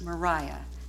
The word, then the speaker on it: Maria: Now, her name is spelled Maria, but it was pronounced Mariah, and even that had a tang to it and a little touch of salt air.